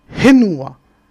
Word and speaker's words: Henua: Donc, Fenua, il n'y a que les trois îles du sud qui le prononcent le F. Et dans le sud, dans le nord, Henua, le H. La lettre F ne se prononce uniquement que dans le sud des Marquises.